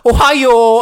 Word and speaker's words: Ohio: Aber alle sagen Ohio. Also so, es fließt so aus.